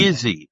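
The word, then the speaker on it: Gizzi: Bob, it's my pleasure, and it's a hard G on Gizzy. And, Gizzy, okay.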